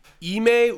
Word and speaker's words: Ime: Ime Udoka.